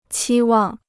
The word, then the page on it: 期望: qī wàng